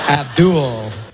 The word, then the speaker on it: Abdul: Abdul. – That's how it's pronounced. – Holds on to the L a little too long.